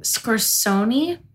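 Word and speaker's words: Scorsone: How do you say her last name? – Scorsoni.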